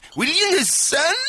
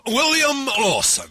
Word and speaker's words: William Lawson: Quand on va discuter avec les gens qui travaillent à la distillerie William Lawson, la première des choses est de bien savoir prononcer son nom. Ça peut faciliter le contact. William Lawson? – Non, William Lawson. – Ok, ok, ok. William Lawson? – Oh, William Lawson. – Ah oui, d'accord, mais j'ai compris, attends. William Lawson? – Non. William Lawson. – C'est marrant, j'étais persuadé de bien le prononcer.